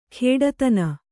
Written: ಖೇಡತನ

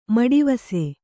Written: ಮಡಿವಾಸು

